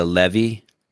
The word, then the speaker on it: Leve: Flow Grappling was nice enough to make it for free on YouTube and type in Amanda Leve. I said it right, right? – Yep. – Amanda Leve, just like it sounds, Amanda Leva.